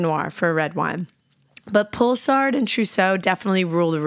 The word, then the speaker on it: Poulsard: So the first varietal is called Pulsard. It's spelled Poulsard.